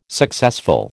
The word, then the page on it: successful: American English